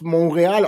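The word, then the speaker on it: Montréal: Und natürlich, die Stadt Montreal gibt es, wird aber eigentlich dort Montreal ausgesprochen, weil es in Französisch Kanada ist.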